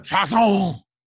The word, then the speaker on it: Chiasson: I'm going to say his name is Kalavon Chiasson. – Chasson. It's French.